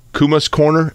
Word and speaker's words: Kuma's Corner: I'm going to mispronounce it probably. Kuma's Corner is that how you say oh, it? Sure, yeah.